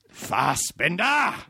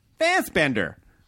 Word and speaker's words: Fassbender: I put the emphasis on the second one instead of the... Well, I mean, I'd imagine Americans are like, Fassbender. – That works too.